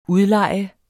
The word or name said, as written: udlejer